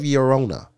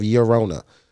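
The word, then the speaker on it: Llorona: So then he was like, yo, Tears of Yerona. It's not the L is silent, so it's Tears of Yerona.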